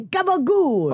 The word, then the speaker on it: gabagool: No, it's spelled capicola, and it's pronounced, if you're authentic, gabagool.